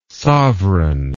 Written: sovran

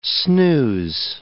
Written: snooze

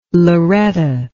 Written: Lauretta

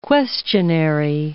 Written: questionary

